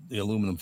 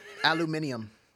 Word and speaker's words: aluminium: And by the way, still annoys me that Brits insist that word is pronounced how? Aluminum. – Aluminium. – Aluminium. Where do you get that extra I? Where is it? I can't find it.